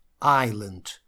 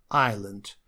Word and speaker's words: island: Ну и здесь единственная особенность, что здесь у нас есть лишняя буква S, которая не читается в этом слове. То есть это не Iceland. Iceland – это у нас Исландия. Это island. Надо просто запомнить произношение этого слова.